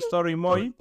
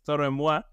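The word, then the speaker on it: Toro y Moi: Normalmente le decimos Story Moi, que es Toro en Moi.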